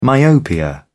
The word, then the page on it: myopia: British English